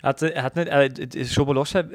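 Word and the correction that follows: Szoboszlai: Dominik Schoboschlei. Schoboschlei.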